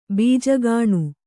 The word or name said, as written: ಬೀಜಗಾಣು